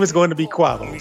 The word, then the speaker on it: Quavo: How do you pronounce his name? – It's Quavo.